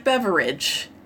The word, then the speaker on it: BeveRAGE: Spelled like beverage, but pronounced like BeveRAGE.